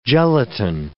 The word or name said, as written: gelatin